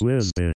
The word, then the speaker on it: WZBT: WZBT. Pronounced Wizbit.